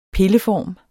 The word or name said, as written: pilleform